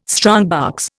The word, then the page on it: strongbox: Indian